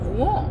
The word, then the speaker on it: Rouen: Äh, denn wir waren heute in Rouen.